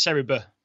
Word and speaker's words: Serie B: The one other nominee it's pronounced Serie B.